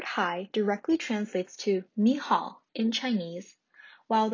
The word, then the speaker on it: 你好: in Chinese goes like this: "ni hao."